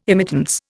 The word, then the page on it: immittance: Indian